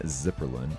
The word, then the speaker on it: Zipperlen: her name is Morgan Zipperlen. That's how you say your last name, right? – Yes, that's it.